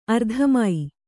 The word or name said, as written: ಅರ್ಧಮೈ